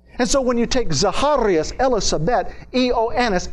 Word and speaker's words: Zacharias: Zechariah in Greek is pronounced Zacharias. That's his name in the Greek, Zacharias.